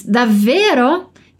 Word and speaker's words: davvero: Aqui essa palavra tem dois Vs, por isso aquela caprichada na pronúncia delle doppie consonanti, as duplas consoantes. Assim, davvero, davvero B, né?